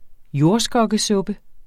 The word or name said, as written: jordskokkesuppe